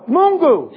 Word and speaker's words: Mungu: And he will always be Mwana wa Miungu. Oh, I said it wrong? Mwana wa Miungu? Oh, Mungu.